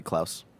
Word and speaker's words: Klaus: The choices are How to Train Your Dragon, The Hidden World, I Lost My Body, Claws, Missing Link, Toy Story 4. – Really quick, I think that's supposed to be Klaus. – What's that? – It is Klaus.